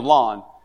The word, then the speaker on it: Lon: Most of you don't know me, but my name is Lon. And I always tell people, remember my name is like a lawnmower. You know, lawnmower is spelled L-A-W, right? N.